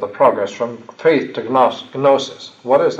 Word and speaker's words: gnosis: gnosis. Gnosis spelled with a G, gnosis, G-N-O-S-I-S, see.